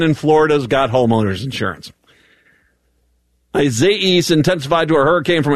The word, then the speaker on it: Isaias: The E at the end of it is the big pronunciation, Isaias. It heads Isaias. Okay. That sounds like an AIA in the middle of it.